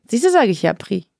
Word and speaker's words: Prix: Pre-Europe, spricht man das so aus? – Den kenne ich nicht. – P-R-I-X, spricht man das dann Pre-Europe aus?